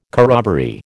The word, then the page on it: corroboree: American English